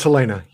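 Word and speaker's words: Halyna: Do you know how to say it? Is it Helena? – It's Helena, yes.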